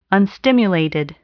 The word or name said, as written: unstimulated